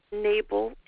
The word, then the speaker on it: Knable: Nable is a silent K, KNABLE.